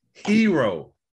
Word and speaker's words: Hero: So I changed my name completely when I go in Starbucks to, I just said my name is Hero, H-E-R-O.